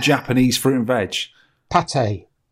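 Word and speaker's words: pâté: And anyway, it's oh, pronounced oh, pate.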